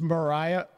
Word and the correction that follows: Moriah: Formica. And I'm sure I'm pronouncing it wrong. – It's Moriah Formica. – Is it Moriah? I'm not sure. – It's M-O-R-I-A-H.